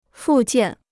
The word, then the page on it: 附件: fù jiàn